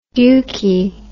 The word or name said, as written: dukey